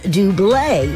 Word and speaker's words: Double: And yes, it is pronounced Dublé. Even though it's spelled D-O-U-B-L-E, which in the U.S.